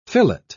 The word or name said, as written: fillet